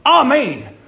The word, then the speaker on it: amen: I wish the Baptists in the South would say, amen. Because that's how you pronounce it in Hebrew and in Greek.